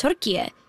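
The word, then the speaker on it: Türkiye: We're supposed to go to Turkey, which is now pronounced Turkiye.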